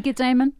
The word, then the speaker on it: Ammon: The kind of amazing, this month-long trial against Ammon and, is that how you say it? Ammon? Ammon Bundy? – I think it's Ammon.